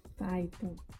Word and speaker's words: Python: Professora de linguagem e programação Fanta IR, eu acertei a pronúncia? É isso mesmo? – Python. – Python, é. desculpa.